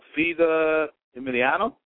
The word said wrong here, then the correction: Fedor Emelianenko: And, of course, Muhammad Ali. – Fedor Emelianenko. Sorry.